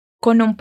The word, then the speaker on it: con un: Quando você fala con, un, você une o som do N com o som do U e na fala fica parecendo como se essas duas palavras fossem uma só.